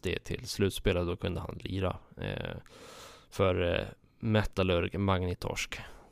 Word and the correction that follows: Magnitogorsk: Eller Magnitogorsk heter de. Ja, du ser. – Bra uttal.